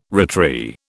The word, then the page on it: retry: British English